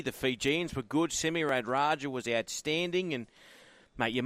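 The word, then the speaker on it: Radradra: Guys, can I just preface this, that Semi Radraja, and that was the pronunciation last night, as it has been through the entire World Cup, was just something else in Fiji's eventual loss to Wales.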